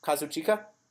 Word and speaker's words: Kazuchika: Kazuchika? – There you go.